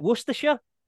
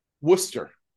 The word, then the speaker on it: Worcester: So how do you say that? Worcestershire? – Worcester. – Worcester, you got me.